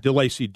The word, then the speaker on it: DeLacy: My contact information is at DeLacy Consulting. – Can you spell that? Your name? – Yeah, DeLacy, D-E-L-A-C-Y.